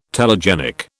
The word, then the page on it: telegenic: British English